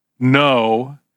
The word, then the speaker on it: know: G-N-O-S-I-S. So the English word know, we don't say canal, do we? Because we learned if we had good teachers in the third grade, the teacher told us and said, the K is silent. Oh, so we pronounce it now? You pronounce it K-N-O-W.